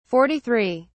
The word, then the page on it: 43: British English